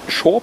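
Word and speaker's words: Saor: Die Band spricht sich Shore Patrol.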